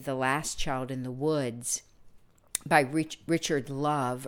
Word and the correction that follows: Louv: or Louv, I believe his name is pronounced Louv, L O U V.